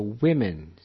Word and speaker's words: women: Remember, you pronounce woman is pronounced woman, and plural women, which is pronounced like W-E-M-E-N, so women.